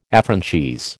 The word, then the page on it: affranchise: American English